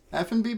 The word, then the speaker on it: Effanbee: I spent way too long trying to figure this out because it's spelled E-F-F-A-N-B-E-E, and I was thinking, is that Italian or French or stuff? But it was actually the initials of the maker, literally F and B.